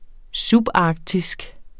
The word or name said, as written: subarktisk